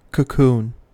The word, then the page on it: cocoon: American English